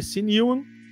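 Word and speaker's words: Sinewan: O Charlie C. Newan, a pronúncia é essa mesmo, C. Newan,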